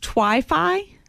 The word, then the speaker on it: Twifi: I think it's pronounced Twi-Fi. T-W-I-F-I.